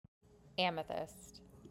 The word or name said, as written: Amethyst